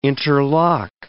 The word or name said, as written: interlock